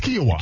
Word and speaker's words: Kiawah: Kiawah. Yeah. – Kiawah Island. – It's probably similar to something you put in your shake every day. – Quinoa. So it sounds like quinoa, but it's Kiawah.